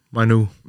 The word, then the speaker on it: Mainoo: Altså to ord i min verden, det bliver til, til et U. Mainu.